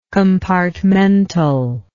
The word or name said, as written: compartmentally